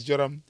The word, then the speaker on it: Joram: That's how it's pronounced, guy. Who is the expert on the Jewish language in this group? It's me. – Wait, don't ask. – I always give you the... – Now it's oh God. – I always give you the right pronunciation, man. It's Joram.